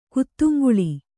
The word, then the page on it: ಕುತ್ತುಂಗುಳಿ: kuttuuŋguḷi